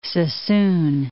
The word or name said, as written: Suisun Bay